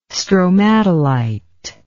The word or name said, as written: stromatolite